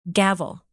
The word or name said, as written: gavel